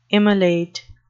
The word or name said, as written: immolate